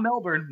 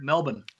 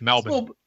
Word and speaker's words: Melbourne: Not Melbourne, Melbourne. – I thought I said Melbourne. – That's a good pronunciation. I like that. Melbourne. That's it. It's Melbourne.